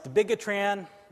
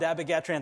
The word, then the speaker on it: dabigatran: Dabigatran, you know, it's not dabigatran, it's dabigatran. That's the way the official pronunciation, at least the company that came up with this, calls it.